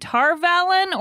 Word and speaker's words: Tar Valon: And she also co hosts the podcasts Desi Geek Girls and Tar Valon or Bust. Did I say that right? You did.